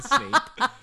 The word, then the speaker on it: Snape: Snipe, Severus Snape. I also don't really watch Harry Potter either. Did I say it wrong? Yeah. Snape? – It's definitely Snape.